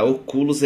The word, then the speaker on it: Oculus: A Oculus é a fabricante, o Quest é o produto.